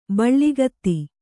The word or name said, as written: ಬೆಳುಗತ್ತೆ